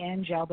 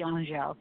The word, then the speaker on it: Angele: That's, some people call me Angele, but it should be Angele.